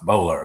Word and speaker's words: Boulware: It's spelled Boulware, but it's pronounced Bowler, apparently, who had been a drug smuggler.